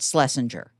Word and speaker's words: Schlesinger: So the weird thing is that, so the name has really totally been anglicized and we pronounce it Schlesinger as if there were no CH.